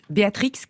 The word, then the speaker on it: Béatrix: Mais lui voulait qu'on prononce Béatrix comme perdri.